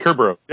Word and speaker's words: Kerberos: So let's come a little forward now and talk about Kerberos. I said that right? Right? – Kerberos. Yep, Kerberos. Well done.